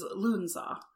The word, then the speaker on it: Lughnasadh: The name that I prefer is Lughnasadh. – Lughnasadh. – Which I'm probably going to catch shit for that too. But that's the way that I have seen it pronounced.